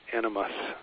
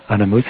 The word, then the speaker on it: animas: I don't pronounce it well myself, but it's animas. A-N-I-M-A-S. It's not the Jungian word animus, which means essentially the masculine inside of a woman. It's souls. Yeah, animas.